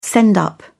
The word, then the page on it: send up: British English